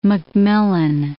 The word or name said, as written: McMillan